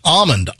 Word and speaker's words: almond: No, I don't pronounce the L. I go, it's almond. Almond. So almond, not almond. Almond. Not. I don't say the L.